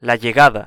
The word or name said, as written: la llegada